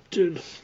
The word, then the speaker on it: Dunne: Is that how you say his name? Or Dune? – Dune.